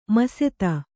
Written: ಮಸೆತ

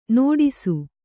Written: ನೋಡಿಸು

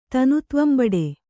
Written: ತನುತ್ವಂಬಡೆ

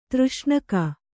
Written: ತೃಷ್ಣಕ

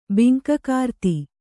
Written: ಬಿಂಕಗಾರ್ತಿ

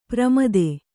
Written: ಪ್ರಮದೆ

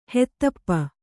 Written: ಹೊದಪು

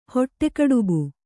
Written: ಹೊಟ್ಟೆಕಡುಬು

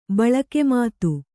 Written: ಬಳಕೆಮಾತು

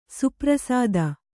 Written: ಸುಪ್ರಸಾದಿ

